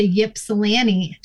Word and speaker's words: Ypsilanti: Do we move to Ann Arbor or do we move to Ypsilanti? She was like, the Y is silent.